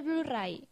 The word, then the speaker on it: Blu-ray: Porque, ¿y qué ha pasado con el Blu-ray? Que no, ¿no? – O oh, el Blu-ray, muy bien pronunciado.